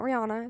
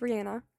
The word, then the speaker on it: Rihanna: So we're listening to Don't Stop the Music by Rihanna, because I said it wrong before. It's not Rihanna, it's Rihanna.